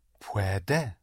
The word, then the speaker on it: puede: Puede. – But only one stress. – Puede. – Right. Puede. Puede. To do or to make is hacer. Spelled H-A-C-E-R.